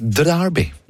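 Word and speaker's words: D'Arby: Akkurat som Terence Darby. Der uttaler man D-en, ja. – Terence Trent Darby, ja, der det D.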